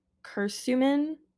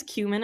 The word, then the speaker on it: curcumin: And then we have, I think it's pronounced curcumin. Curcumin. I thought it was cumin at first, but there's a C-U-R in front of it.